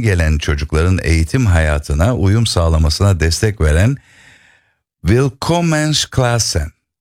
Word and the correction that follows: Willkommensklassen: telaffuzunu söyleyeyim bana. – Willkommensklassen. – Willkommensklassen. Evet, benimki olmamış.